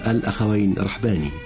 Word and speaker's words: الأخوين رحباني: فيروز، هذا الجسد النحيل والملامح التي تعبر عن قلق الفنان في انتقاء كل ما يقدمه للإنسان وللإنسانية، فيروز المبدعة اكتشفها الأخوين رحباني، ونحن نلتزم بالنطق الذي اشتهر به هذا الاسم الأخوين رحباني، ننطقها هكذا في كل حالات الإعراب عند الرفع وعند النصب وعند الجر.